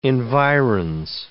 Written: environs

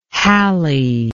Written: hallie

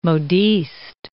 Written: modiste

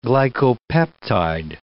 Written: glycopeptide

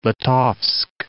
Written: Brest Litovsk

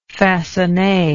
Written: faconne

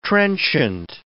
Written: trenchant

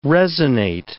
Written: resinate

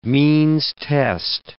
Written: means test

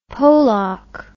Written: Polock

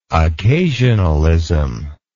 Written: occasionalism

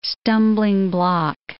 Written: stumbling block

